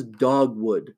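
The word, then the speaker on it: Dogwould: Second one I'm going to mention is Dogwood, which is spelled kind of funky. It's all one word, D-O-G, capital W-O-U-L-D, like a dogwood.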